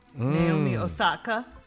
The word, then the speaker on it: Osaka: Naomi Osaka.